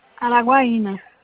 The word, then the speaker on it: Araguaína: O motivo do meu contato é referente a uma ação com os servidores de Araguaiana. É Araguaiana que se fala mesmo, né? Tô falando certo. – Araguaína. – Araguaína. Ah, sim, tem um acento aqui.